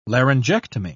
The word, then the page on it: laryngectomy: British English